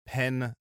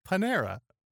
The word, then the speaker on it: Panera: I'm just snagged on the idea of pronouncing it Pen-nera. – Pen-nera. How do you pronounce it? – Panera.